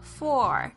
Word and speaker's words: for: 在这边呢你看发现老师念的时候呢，我不是念成 for 这样子很清楚很圆的这种声音，我是念成 f。当这个 for 当它在这个句子中间的时候呢，它常常呢就是跟这个正常语速在发音的时候呢，它其实不是念成 for 这样的发音，而是念的这样子 f 这样子的声音。